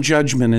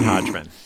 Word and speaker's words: Hodgman: The Judge John Judgman is the most common mispronunciation of the admittedly hard to pronounce show title.